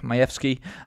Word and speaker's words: Majewski: A lot of people pronounce it Jack Majewski or Jack Majewski, but he's Polish and it's actually pronounced Majewski.